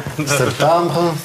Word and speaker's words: septembre: Септембр.